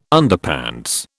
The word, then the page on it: Underpants: British English